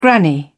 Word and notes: granny: British English

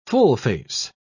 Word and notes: full-face: British English

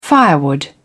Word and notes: firewood: British English

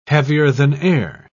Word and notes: heavier-than-air: British English

